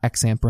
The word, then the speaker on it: XAMPP: It's called XAMPP, X-A-M-P-P. I'm pretty sure that's how it's pronounced. It's not XAMPP or anything. I'm pretty sure it's XAMPP. It's X-A-M-P-P.